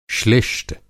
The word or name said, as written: श्लिष्ट